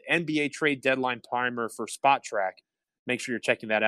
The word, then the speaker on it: Spotrac: And I always feel like I mispronounce this, Spotrac or Spot track. Okay, so you say the T twice.